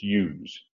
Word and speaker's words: ewes: I know how to pronounce it. It's ewes.